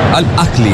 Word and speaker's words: Al Ahly: Al Ali juega ahora. Al Ahly, en realidad, como se debe pronunciar.